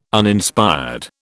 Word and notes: Uninspired: British English

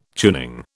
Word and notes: tuning: British English

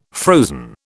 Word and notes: frozen: British English

